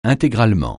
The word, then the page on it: intégralement: francais